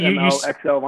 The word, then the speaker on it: SMLXL: Just like that. – You say SMLXL vinyl. – You spell it out.